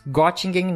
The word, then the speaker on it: Göttingen: Tem pós-doutorado pela George August, agora eu não sei universitar, não sei como é que eu falo isso, Göttingen, na Alemanha. Falei certo? – É, é tá certo, Universität eles falam, mas tá tudo bem.